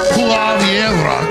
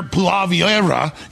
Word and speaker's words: Poilievre: So, Pierre Poilievre, I got that right.